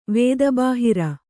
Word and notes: ವೇದಬಾಹಿರ: vēda bāhira

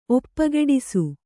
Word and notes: ಒಪ್ಪಗೆಡಿಸು: Kannada

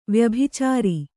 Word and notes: ವ್ಯಭಿಚಾರ: vyabhicāra

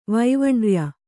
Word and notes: ವೈವಣ್ರ್ಯ: vaivarṇya

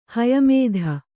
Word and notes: ಹಯಮೇಧ: haya mēdha

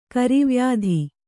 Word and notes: ಕರಿವ್ಯಾಧಿ: kari vyādhi

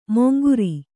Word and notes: ಮೊಂಗುರಿ: monguri

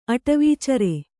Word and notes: ಅಟವೀಚರ: aṭavīcara; Kannada

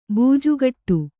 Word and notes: ಬೂಜುಗಟ್ಟು: būjugaṭṭu

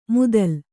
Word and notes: ಮುತ್ತಲ: muttala

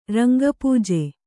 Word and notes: ರಂಗಪೂಜೆ: Kannada

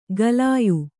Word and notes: ಗಲಾಯು: galāyu

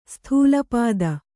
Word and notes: ಸ್ಥೂಲಪಾದ: Kannada

